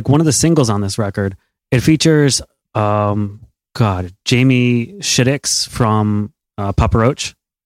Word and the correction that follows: Jacoby Shaddix: Yeah, Jacoby Shaddix, that's how you say it.